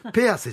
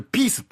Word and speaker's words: ピース: ほら、今日俺、ピース持ってきたぞ。それはペアセじゃないかいや、徹黙ってる、お前。 – ペアセじゃない。それピースって読むんだよ、そりゃ。